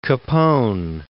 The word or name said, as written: Capone